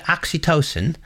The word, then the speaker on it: Oxytocin: But assuming that there's niceness to work with to begin with, they're talking about, am I pronouncing it right? Oxytocin. – Correct, yeah.